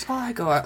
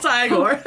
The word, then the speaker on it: Igor: It's pronounced Igor. Oh, Igor. They said it was Igor. Well, they were wrong then. Right? Okay. – It's Igor.